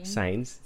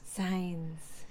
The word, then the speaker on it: Sainz: Sainz. Sainz.